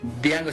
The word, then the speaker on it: Django: Está Django Sin Cadenas, Django Unchained, de Quentin Tarantino. – La D no se pronuncia.